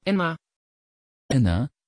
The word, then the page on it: énna: Dutch